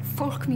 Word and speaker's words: folk: Get that L in folk.